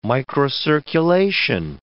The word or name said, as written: microcirculation